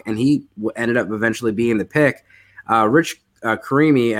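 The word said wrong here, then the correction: Cimini: I'm going to say that wrong. He covers the Jets, and he talked about – Rich Samini. – Yeah. Samini. Mm-hmm.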